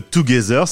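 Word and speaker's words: Togezer: Est-ce que tu peux me parler un peu de Together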